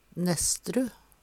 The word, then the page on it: Nesstru: Numedalsmål